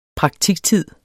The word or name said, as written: praktiktid